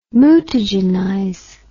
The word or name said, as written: mutagenize